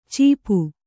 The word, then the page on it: ಚೀಪು: Kannada